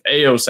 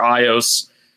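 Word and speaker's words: Aeos: It's called Eos energy, I think it's how it's pronounced, or Eos. It's A-E-O-S.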